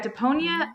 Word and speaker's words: Deponia: Am I saying that right? – Yeah, Deponia. I don't know.